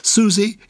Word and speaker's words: Susie: And Susie is spelled S-U-S-I-E, not S-U-Z-I-E. – Yes, S, not S-U-Z.